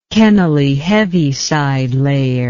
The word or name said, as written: Kennelly-Heaviside layer